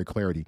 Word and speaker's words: Clardy: Give me a follow too, at Troy Clarity. Last name is spelled C-L-A-R-D-Y.